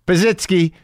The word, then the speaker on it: Pazsitzky: Man, I better pronounce that right. Pazitsky, P-A-Z-S-I-T-Z-K-Y, Christina Pazitsky, Pazitsky. We talk about it. I think that's it. I think I got it.